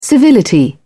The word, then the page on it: civility: British English